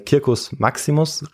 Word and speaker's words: Circus Maximus: Und zwischen dem Aventin und dem Palatin, nahe des Forum Boariums und unweit des Tibers, lag der Circus Maximus. – Mhm. Oder Circus Maximus, richtiger ausgesprochen. Aber ähm, ich werde es jetzt Circus Maximus aussprechen, weil es intuitiver ist.